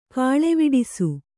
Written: ಕೀಳ್ವಡಿಸು

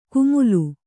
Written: ಕುಮುಲು

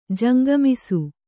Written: ಜಂಗಮಿಸು

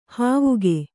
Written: ಹಾವಿಗೆ

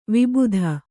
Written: ವಿಭೂತಿ

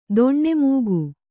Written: ದೊಣ್ಣೆಮೂಗು

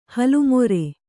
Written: ಹಲ್ಲುಮುರಿ

